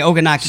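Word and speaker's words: Ogunnaike: Oh, is it Oganuk? Oganaki? – Yeah, Oganaki.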